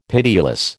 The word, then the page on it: pitiless: American English